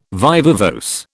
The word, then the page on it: viva voce: British English